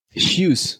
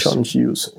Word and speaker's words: Hughes: Wie spricht man den Namen eigentlich wirklich aus? – Hughes, okay. John Hughes. – John Hughes, ja.